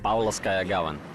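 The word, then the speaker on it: Pavlovskaya Gavan (Павловская Гавань): Pavlovskaya Gavan. – Eso es. Así se llamaba entonces. En la década de 1790, el asentamiento se trasladó de la Bahía de los Tres Santos a Pavlovskaya Gavan, actualmente conocida como St. Paul's Harbor.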